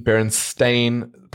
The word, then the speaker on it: Berenstain: but his name has always been Berenstain, pronounced stain, as in coffee stain, and he has been confused and pronounced Berenstain over the years, especially when he was younger.